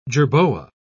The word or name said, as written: jerboa